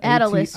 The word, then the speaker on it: Atilis: Attalis Gym. A-T-I-L-I-S. Mm-hmm. It sounds like Atlas, because there was a gym, Atlas.